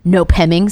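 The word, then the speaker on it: Nopeming: And because of that, we are now going to delve into, at least on Hulu, season 13, episode 10, Nope Hemming Sanatorium. – Nopingham. – Nope-ming. – Nope-ming? – That's how it's spelled. Nope-ming. Like Noming. But it's Nope-hemming.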